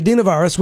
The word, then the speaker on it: adenovirus: Uh, research is continuing, but experts believe that the hepatitis spike is linked to the adenovirus. I said it wrong. The adenovirus, which is uh, like the common cold.